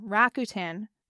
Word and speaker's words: Rakuten: Also, another not ad is I'm sitting here, ironically, with my next check from Rakuten. I always say it wrong. Rakuten.